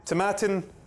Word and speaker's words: Tomatin: Tomatin rhymes with satin, smooth like satin, okay? – Oh.